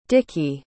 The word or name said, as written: deke